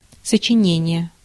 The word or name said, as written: composition